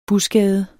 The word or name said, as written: busgade